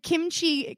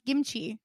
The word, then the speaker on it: kimchi: And kimchi can also be pronounced gimchi. Like the G and the K.